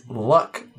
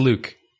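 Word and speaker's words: Luc: "You pronounced Luke Besson's name as Luck, not Luke." Wait, is it Luck? Or is it Luke? – It's Luke.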